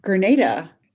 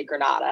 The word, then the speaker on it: Grenada: Well, firstly, shout out to you for saying it correctly, because everyone says Grenada. And they're like, is it Grenada? Grenada? Like, what is it? Or I'll let them know it's Grenada and they'll continue to say Grenada.